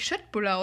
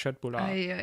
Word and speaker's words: Köttbullar: Ähm, Köttbullar wird doch eigentlich Schöttbullar ausgesprochen, habe ich gehört. – Richtig, das immer wenn das K vor einem. Blown. Immer wenn das im Schwedischen, wenn das K vor einem O oder einem Ö ist, dann wird das wie ein Sch ausgesprochen. Also eigentlich Schöttbullar.